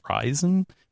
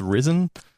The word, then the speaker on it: Ryzen: Ryzen. Oh, I thought, I was like, wait, are you sure it's not pronounced Risen?